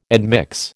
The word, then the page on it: admix: American English